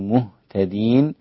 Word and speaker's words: مهتدين: مهتدين. راعي ترقيق الميم في مهتدين حتى لا يكون الضم مدعاه لتفخيم الميم، فيصير النطق بها مهتدين، مو غلط، مهتدين هكذا.